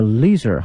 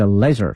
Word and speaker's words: leisure: 美式呢是 leisure 哈，英式呢是 leisure，它的拼写呢是 l e i s u r e 哈。